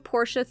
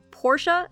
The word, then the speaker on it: Porsche: By the way, if you're wondering why I'm pronouncing it Porsche and not Porsche, it's because Porsche apparently got tired of people saying Porsche, so they made a video telling all of us that we should be pronouncing it Porsche.